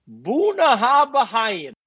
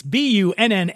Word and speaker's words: Bunnahabhain: Because it looks like Bunnahabhain. – It's Bunnahabhain. It's Bunnahabhain.